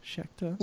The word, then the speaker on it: Schecter: Schecter? – Yes, you have.